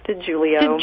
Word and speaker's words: DeJulio: It's actually, it looks exactly like you said it, but it's actually pronounced DeJulio.